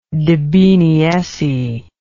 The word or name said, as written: debeneesse